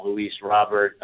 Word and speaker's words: Luis Robert: So, but I've always heard as Luis Robert.